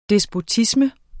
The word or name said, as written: despotisme